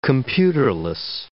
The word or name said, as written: computerless